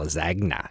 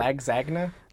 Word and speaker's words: lasagna: She's pronounced the G somehow. Lasagna. It was weird. – Lagzagna? – It wasn't unlike that.